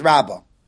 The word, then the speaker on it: Rabba: And when we say the one with the Hey, it's Rabba.